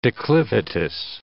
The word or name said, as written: declivitous